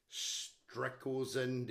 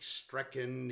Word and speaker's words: Strakhuizen: He's a sort of a stalwart of the South Australian motor racing scene, and no commentator anywhere in Australia can actually pronounce his name. He's Nick Strakhuizen, Nick Strecken, Nick Strecko, Nick Stratco. Thank God I know his name.